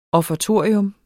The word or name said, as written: offertorium